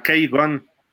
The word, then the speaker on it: K-Gun: O la, va, a sonar feo, pero así se pronuncia en inglés, la K-Gun. Es la K de Kelly y Gun de escopeta, el Machine Gun Kelly le decían.